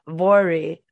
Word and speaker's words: Voirrey: The Irving family consisted of James, the father, Margaret, the mom, and I looked this up and Googled it and listened to the pronunciation so I wouldn't mess it up, Vori, the daughter. It's spelled V-O-I-R-R-E-Y. So I was like, that's a really weird name.